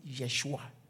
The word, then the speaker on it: Yeshua: It's Y-H-W-H. Yahweh, which is J, German. The Y is J, Jehovah. So for instance, when we say Joshua in the Hebrew, is actually Yeshua, not Joshua.